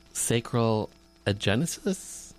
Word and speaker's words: sacral agenesis: Spencer, you were born with sacral agenesis? – Mm-hmm. – Did I say that correctly? – What, you did.